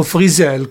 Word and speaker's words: Frizzell: Lefty Frizzell, o Frizzell come ho scoperto che si dovrebbe pronunciare,